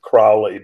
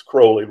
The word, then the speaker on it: Crowley: People sometimes pronounce it Crowley, but it's Crowley, rhymes with holy.